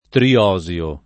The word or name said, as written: triosio